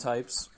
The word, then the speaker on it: TTTTYPES: It's just pronounced Ttypes, but there are multiple T's.